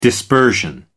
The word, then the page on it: dispersion: American English